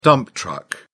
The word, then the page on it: dump-truck: British English